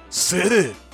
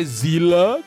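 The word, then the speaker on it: Seele: É, se o nome é no alemão, a pronúncia correta é Zilla, com um azinho meio disfarçado no final.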